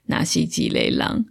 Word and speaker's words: 若是一個人: 那这一部刚刚说它叫做《若是一个人》，那如果用台语发音的话，就是《纳西吉雷郎》。